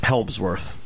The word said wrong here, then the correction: Hemsworth: Christopher Helmsworth, right? – Yes. – Thor. – Chris Hemsworth, yeah.